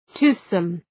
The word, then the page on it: toothsome: ‘tu:ɵsəm